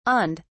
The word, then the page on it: awned: British English